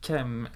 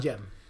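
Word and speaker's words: Cem: ja, men vi kan gå vidare till, eh, till Turkiets andra singel där som Kem, eller Kem Ilkel, eller hur uttalar vi det? Jem. Jem. Jem är J. Okej. Okay. Stavas med C.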